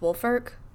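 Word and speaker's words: Woolfolk: It doesn't look like it's pronounced Wolfolk, but it is.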